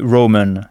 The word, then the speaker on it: Roman: Et romain se dit Roman. C'est presque identique au français, mais sans le i. Roman, R-O-M-A-N.